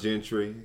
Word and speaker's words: Gentry: You find me on Facebook using my real name, Tyvon Gentry. Spell that T-Y-V-O-N, last name Gentry, G-E-N-T-R-Y.